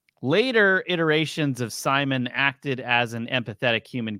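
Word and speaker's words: CIMON: In 2018, the ESA debuted the Crew Interactive Mobile Companion, known as Simon, which is spelled C-I-M-O-N, a round volleyball-like computer.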